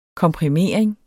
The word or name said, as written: komprimering